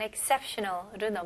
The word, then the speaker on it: exceptional: 다음은 exceptional로 넘어갈게요. 발음 연습부터 해볼게요. Exceptional. Exceptional. 네, 여기서 EX로 시작하지만 exceptional보다는 exceptional. I 발음으로 주로 많이 발음합니다.